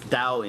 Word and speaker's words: Tao: Well, actually, the Mandarin spelling should be a D. It's pronounced Dao in Mandarin, right? But it's written T in English because of, I think, the old Wade Giles way of spelling it or whatever.